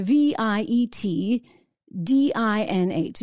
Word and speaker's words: Viet Dinh: V I E T D I N H